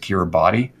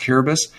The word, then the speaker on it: Kiribati: You might pronounce it Kiribati, but it's actually that's how it looks like it's spelled. But it's actually pronounced Kiribati.